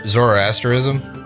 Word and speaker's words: Zoroastrianism: Zoroastrianism.